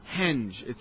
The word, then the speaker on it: Stonehenge: Stonehenge proper. So, did I say Hinge? Henge. It's spelled with an E. Stonehenge.